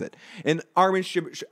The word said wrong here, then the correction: Shimerman: Armin Shimerman, uh, amazing on Buffy and other things he's been in as well.